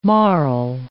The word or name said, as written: marl